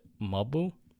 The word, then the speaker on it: Mob: They pronounce it Mobu.